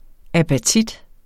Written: apatit

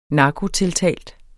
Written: narkotiltalt

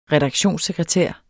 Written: redaktionssekretær

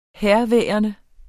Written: herværende

